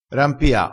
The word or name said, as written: rampia